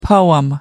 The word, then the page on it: poem: American English